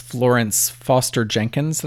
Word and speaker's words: Florence Foster Jenkins: One was Florence Foster Jenkins. Did I say that right? Is that right? – Yeah.